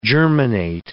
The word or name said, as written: germinate